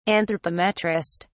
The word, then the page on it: anthropometrist: British English